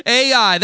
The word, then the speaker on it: Ai: The next city after Jericho is a tiny little spot on the map, Ai, that little city. That's the way you say it, the way it's spelled, Ai, praise the Lord.